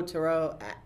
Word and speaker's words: tarot: Tarot. It's French. You know, it's just like Ouija. Ouija is actually how it's pronounced. It doesn't really matter to me. – As long as it's not tarot. The T is always silent.